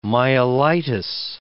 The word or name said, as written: myelitis